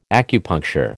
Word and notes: acupuncture: American English

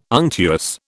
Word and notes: unctuous: British English

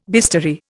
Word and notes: bistoury: Indian

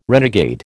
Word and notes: renegade: American English

